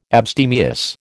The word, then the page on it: abstemious: American English